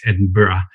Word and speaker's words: Edinburgh: By the way, it's Edinburgh. But you never ask an Englishman to correct a pronunciation to an American. So forgive me for that.